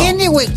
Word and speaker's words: Kennewick: Así lo dije bien ahora sí. Kennewick, así está bien dicho, ¿no? – Kennewick.